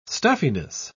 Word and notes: stuffiness: British English

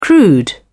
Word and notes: crude: British English

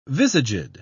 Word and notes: visaged: British English

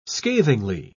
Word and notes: scathingly: British English

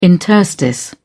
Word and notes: interstice: British English

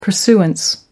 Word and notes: pursuance: American English

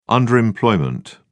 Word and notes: underemployment: British English

